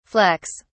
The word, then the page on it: flecks: British English